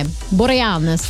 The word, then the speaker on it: Boreanaz: I just always have Boreanaz. – Yeah. Boreanaz.